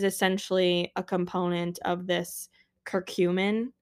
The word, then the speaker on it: curcumin: And then we have, I think it's pronounced curcumin. Curcumin. I thought it was cumin at first, but there's a C-U-R in front of it.